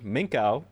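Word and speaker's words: Minkow: From here, Minko, I keep pronouncing it wrong because it's M-I-N-K-O-W, so my brain. – Just keep thinking Krakow in your brain.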